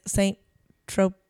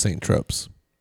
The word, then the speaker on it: Saint-Tropez: In French it said as Saint-Tropez. Saint-Tropez. – In your face. – In English, Saint-Tropez. Saint-Tropez from French, Saint-Tropez. – That's just what I said. Saint-Tropez.